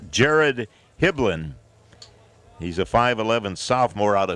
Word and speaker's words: Hibbeln: Spells his last name H-I-B-B-E-L-N. Pronounced Hiblin. And he is the new pitcher.